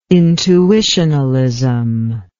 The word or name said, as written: intuitionalism